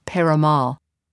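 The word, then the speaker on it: Peyramale: That's Abbe, I think I said Pemeral, and it was Peramal.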